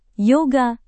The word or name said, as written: yoga